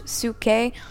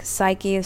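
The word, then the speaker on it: psuche (ψυχή): It's pronounced psuche, but it looks like psyche.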